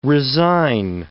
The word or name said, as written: resign